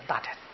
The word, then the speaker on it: 大乘: 第三段呢，讲大乘，啊，大乘是比喻，啊，这个乘呢，是在讲古音念声，当作动词讲的，啊，但是现在呢，大家都念成了，咱们也就念成吧，啊，反正只要意思没搞错。也就可以了，我们就随俗了啊，念大乘啊，这就是贴乘啊，是乘以运载为意呀啊，它是一个交通工具啊，运输的工具。